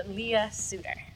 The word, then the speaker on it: Leah Sutter: So, Leah Sutter.